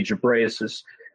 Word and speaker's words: Ghebreyesus: His name, a funny last name, it's pronounced different ways, but typically Ghebreyesus.